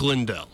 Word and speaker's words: Lindell: I was getting his last name wrong earlier. Mike Lindell, CEO of MyPillow.